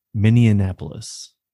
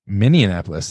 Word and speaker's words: Minneapolis: I've been pronouncing it Minneapolis. – Minneapolis. This is like my entire life. – Minneapolis. Sounds like Indianapolis.